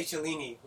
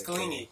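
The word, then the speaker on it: Chiellini: I used to say Cellini with like a CH. Nah, but it's Cellini.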